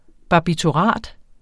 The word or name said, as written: barbiturat